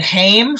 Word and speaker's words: Haim: Isn't there Haim? Haim? – Yeah. – Same way, H-A-I-M, Haim? – Yeah. – So shouldn't this be Haim? – but it's FOM.